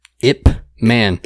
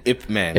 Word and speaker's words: Ip Man: It's really hard to tell somebody what that movie is because I feel like I under-pronunciate the P. Ip Man. – Ip Man. – It almost looks like IP Man. – I used to call it IP Man when I was an ignorant piece of shit.